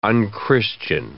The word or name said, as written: unchristian